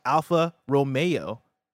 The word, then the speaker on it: Alfa Romeo: And then Alfa Romeo is pronounced Alfa Romeo, like mayonnaise. – Okay. – Thank you, New AJ. I almost went that route, but like there was no accent ague on the E.